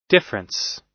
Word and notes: difference: American English

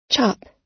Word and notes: chop: American English

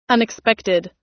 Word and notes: unexpected: American English

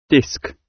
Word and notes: disc: British English

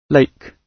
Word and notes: lake: British English

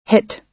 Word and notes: hit: American English

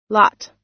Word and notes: lot: American English

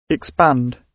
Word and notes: expand: British English

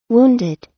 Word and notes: wounded: American English

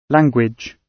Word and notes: language: British English

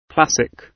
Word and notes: classic: British English

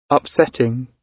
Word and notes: upsetting: British English

